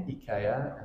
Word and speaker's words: IKEA: Or IKEA, as it's pronounced.